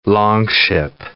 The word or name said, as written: longship